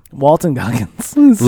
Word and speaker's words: Walton Goggins: I mean, Walter Goggins is just so good. Walton Goggins.